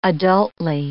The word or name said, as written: adultly